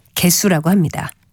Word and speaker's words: 개수: 물론 발음은 둘째 음절을 된 소리로 발음해서 개수라고 합니다.